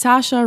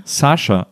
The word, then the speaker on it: Saoirse: Ah, wie spricht man sie aus? – Saoirse Ronan. – Saoirse. Also ja. Eigentlich muss man einfach nur denken wie das deutsche Sascha.